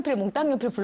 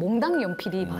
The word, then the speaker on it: 몽당연필: 사실 저희는 어렸을 때는 네. 몽땅연필 불렀잖아요. – 네. – 근데 원래대로 하는 발음은 몽당연필이 맞아요. 표준어로.